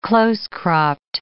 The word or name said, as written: close-cropped